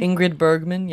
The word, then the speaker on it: Ingrid Bergman: And I'm saying that Ingrid Bergman, am I saying that properly? – Ingrid Bergman, yeah.